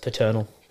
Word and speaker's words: Paternal: It's pronounced paternal. – Is paternal? It? – Yeah, it has a P-A-T. There's no C in it, brother.